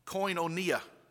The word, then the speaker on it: koinonia: and it is pronounced koinonia. It's a Greek word that has the idea that they had things in common with one another.